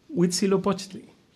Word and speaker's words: Huitzilopochtli: Huitzilopochtli.